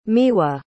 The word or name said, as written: miwa